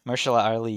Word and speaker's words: Mahershala Ali: Mahershala Ali. – Mahershala Ali.